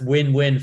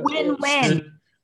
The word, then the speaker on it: WNWN: It's actually pronounced Win Win Food Labs. – And oh, uh, Win Win. Okay. – Yeah. – So but it's good. Lots of people. Lots of people make this. It's hard, It's too harsh to call it a mistake because we spell it WNWN.